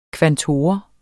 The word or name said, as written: kvantor